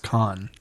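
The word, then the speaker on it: Cannes: The only thing you know is that it's pronounced Con.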